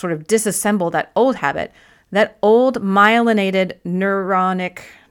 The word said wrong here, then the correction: neuronal: neur, neur, how do you say that? The, the neuronal path, ooh, there we go, neuronal path that has been established is still there.